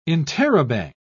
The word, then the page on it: interrobang: British English